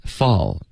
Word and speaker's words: fall: 所以你要注意 ,fall, 结尾 L 的音，舌尖要顶上颚，然后别动嘴唇、,OK?